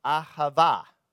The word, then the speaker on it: ahava: And love in Hebrew is pronounced ahava.